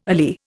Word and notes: alee: Indian

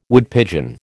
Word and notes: Woodpigeon: American English

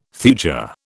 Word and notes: thuja: British English

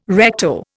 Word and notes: recto: Indian

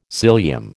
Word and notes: cilium: American English

